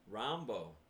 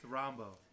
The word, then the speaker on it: Rambo: How about Bakari Rambo? – Rambo. – Mm-hmm. That's how you pronounce it. – Is that how it is? – It's Rambo.